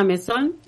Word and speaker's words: Mesón: Me encuentran con mi nombre, Mora Mesón. – Ahí va. – Mesón con S, siempre digo, porque se escribe bien fácil. Así como suena, se escribe el apellido.